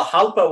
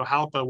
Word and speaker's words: Jalpa: Well, Jalpa would be Spanish, so in Italian, it's Jalpa.